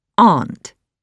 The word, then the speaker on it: aunt: For example, in Singapore and England, we pronounce aunt as aunt. Aunt as in part. In America, the same word is pronounced as aunt. Aunt as in pants.